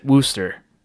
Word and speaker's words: Worcester: So there's a few different ways to pronounce it. And I even told him this. And it is named after an American city, which is in turn named after a British city, I believe, or yeah, something like that. But the British pronounce it Worcester,